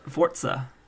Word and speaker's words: Forza: Forza. It's pronounced Forza.